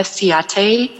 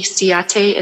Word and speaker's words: Siate: My name is Erica Syed. That's Erica with the C. Siate is spelled S-I-A-T-E.